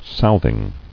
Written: Southing

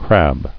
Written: Crabbing

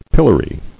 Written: Pilloried

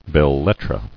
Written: Belles-lettres

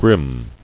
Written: Grimness

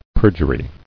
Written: Perjuries

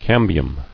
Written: Cambial

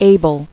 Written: Abler

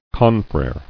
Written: Confrere

